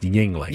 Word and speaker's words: Yuengling: Yeah, it's spelled with a U, but... – A Y. – Well, Y-U, but Y-U. It's pronounced Yuengling.